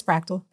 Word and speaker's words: Frakktal: And it's funny because people either always get it or like there's 50-50 people get it immediately and they're like, fractal. Okay. Or they don't pronounce it at all. And I never anticipated somebody with a weird name. I would never create my company with a weird name for it to not be pronounced correctly, but it's fractal. That's it. Like the mathematical concept of fractal, which is just parts repeating themselves in the whole, right?